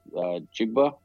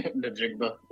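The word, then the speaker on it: Njigba: I think Geno, and they got the receiver that I wanted the Jets to get, Jackson Smith, Jigba. I don't know how to say his last name. – Najigba.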